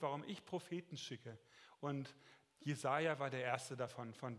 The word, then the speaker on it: Jesaja: Jesaja, ein wunderbarer Name. Man könnte auch sagen Jesaja. Also die Hebräer sagen immer Endbetonung Jesaja äh, heißt Gott, ähm, der Herr ist meine Rettung, der Herr ist Heil, der Herr hilft.